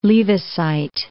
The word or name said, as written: Leavisite